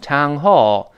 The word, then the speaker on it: town hall: 这个 town hall 是两个词，但后面那个 hall 要稍微重读一点，那个 town 略微轻一点，就读成 town hall。